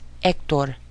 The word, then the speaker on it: Héctor: Now we get to the only silent letter in the Spanish language. It's called H. H. This letter is silent. In other words, you're only pronouncing the E-C-T-O-R without the first letter. So it's Héctor.